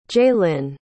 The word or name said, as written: jalynn